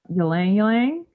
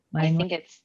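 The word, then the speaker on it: ylang-ylang: Ylang ylang? Is that how you pronounce that? – Lang lang? – Yeah. Is that it? – Lang-lang? I think it's lang lang. – Okay. Don't pronounce the front Y.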